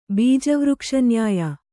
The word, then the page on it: ಬೀಜವೃಕ್ಷನ್ಯಾಯ: bīja vřkṣa nyāya